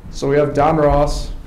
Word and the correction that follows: Donruss: Donruss. I always pronounce it Ross.